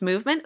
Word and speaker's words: MVMT: Movement, which is spelled MVMT, pronounced Movement, was founded on the belief that style should not break your bank.